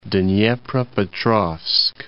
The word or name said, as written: Dnepropetrovsk